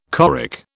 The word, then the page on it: choric: British English